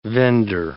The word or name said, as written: vendor